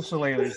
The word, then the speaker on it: Shillelagh: So it's pronounced Jewel Shillelagh.